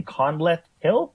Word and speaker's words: Conleth Hill: I believe it's how do you pronounce his name? Conleth Hill? – Mm-hmm.